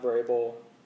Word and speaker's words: Vrabel: He's with the defensive-minded coach and Mike Vrabel.